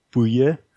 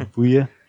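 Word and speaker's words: Buje: It's B-U-J-E. And in here in Denmark, we just say Bouye. So it almost sounds French, even though it's totally not. It's just a weird last name, even in Denmark. So Bouye.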